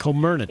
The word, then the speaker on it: Comirnaty: It's called Comirnaty. I think that's how it's pronounced. – Comirnaty.